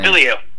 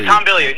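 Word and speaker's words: Bilyeu: Hawk, as you mentioned, Tom, is it Billy? How do you pronounce Tom's last name? – Billy. Yeah, Tom Billy.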